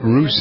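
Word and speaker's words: Ruess: With Nate Roos. Is that how you pronounce his name? – Yep. Russ.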